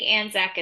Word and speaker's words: Anzac: I pronounce it Anzac in my head, but I don't know if that's right. – I think Anzac is it.